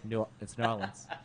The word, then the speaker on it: New Orleans: That's how you say it. New Orleans. – New Orleans. – Yeah, there's nothing new about Orleans. It's New Orleans.